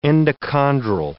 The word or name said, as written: endochondral